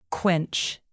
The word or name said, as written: quench